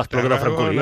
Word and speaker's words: Espluga de Francolí: Pues en tu tierra, la espluga de Francolí, no sé si lo he dicho bien. Sí, ahora la espluga de Francolí, hombre, claro.